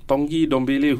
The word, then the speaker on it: Tanguy Ndombele: Yeah, I mean, Spurs' new signing, Tongi Dombele, who a lot of people mispronounce his name because it's spelled less Tanguy Ndombele, but it's a silent N and it's Tongi.